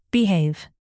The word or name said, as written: behave